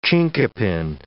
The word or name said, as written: chinquapin